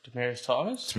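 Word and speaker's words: Demaryius: Before DeMario Thomas. DeMario Thomas? – But yeah, that's all. – He's trying to get his name right. – Demarius Thomas.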